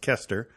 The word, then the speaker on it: Koester: Just for people knowing, it's spelled like Koster, Koester, but it's pronounced Koester.